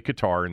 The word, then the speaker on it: Qatar: It is pronounced Qatar. That's the official pronunciation. I know some people say Qatar, and that's fine, too, because we don't care.